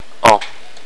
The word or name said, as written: an